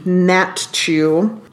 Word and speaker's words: Natiw: We have Jillian Natchu, really unusual spelling of her last name, Natiw. I had to look up the pronunciation, Natchu.